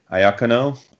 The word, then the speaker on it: Iacono: so I'm going to say Mataya Iacono, Vince, you're the Italian. M-A-T-T-I-A would be Mataya, I assume, and then I-A-C-O-N-O would be Iacono.